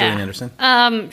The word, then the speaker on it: Gillian Anderson: Gillian Anderson, yes. Did I say that right? – Yeah. – Gillian Anderson.